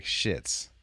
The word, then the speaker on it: Schitz: Then you got Money Hunt, South Beach, going shits, but it's spelled SCHITZ, like shits, like Markovitz shits.